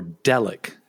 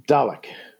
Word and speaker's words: Dalek: So this week we're going to talk about episode 6 from season 1, Dalek, or Delic. How do you pronounce it? – Dalek.